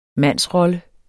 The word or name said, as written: mandsrolle